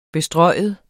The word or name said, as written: bestryge